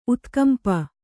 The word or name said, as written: ಉತ್ಕಂಪ